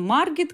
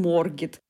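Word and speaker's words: Margit: А А, например, это О, поэтому не такой он Маргит, как он Моргит, остров.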